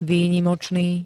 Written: výnimočný

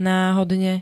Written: náhodne

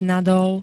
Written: nadol

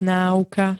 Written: náuka

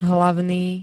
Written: hlavný

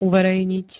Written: uverejniť

